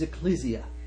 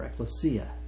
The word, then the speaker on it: ekklesia: The primary word in the Greek for the assembly is ekklesia, or ekklesia, depending upon the Greek pronunciation.